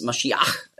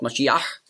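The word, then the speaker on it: Maschiach (משיח): Also darum wurde aus Masch, wurde Mess und dann Iach mit dem Het am Schluss, Maschiach, hat man früher ausgesprochen, Maschiach. Da hatten sie auch Mühe und darum haben sie daraus gemacht Messias.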